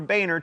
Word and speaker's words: Boehner: Bolstered by 80 or so new Tea Party congresspeople, the Republicans took control of the House in 2010, and John, it's pronounced Boehner, became the Speaker of the House.